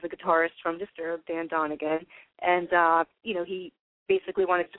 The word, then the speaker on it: Donegan: Dan Donegan? – Dan Donegan, yeah, exactly.